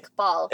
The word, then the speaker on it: Iqbal: Iqbal.